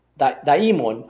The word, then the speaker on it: daimon: D-A-I-M-O-N, or daimon, daimon, I think it might be the better pronunciation, daimon.